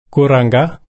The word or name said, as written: kurrangga